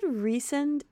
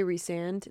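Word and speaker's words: Rhysand: They said Recent in the audiobook for the first book, but later on they say Resand.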